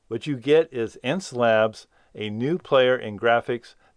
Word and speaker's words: Xencelabs: And it's called Ence Labs Pen Tablet Review, Already Better Than Wacom. And it's spelled X-E-N-C-E-L-A-B-S.